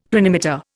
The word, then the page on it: planimeter: Indian